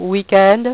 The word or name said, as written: weekend